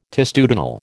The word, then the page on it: testudinal: American English